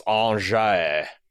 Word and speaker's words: Angers: And then I see Angers, which is pronounced Angers, and I'm like,